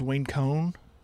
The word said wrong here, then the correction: Wayne Coyne: Wayne Coyne from the Flaming Lips.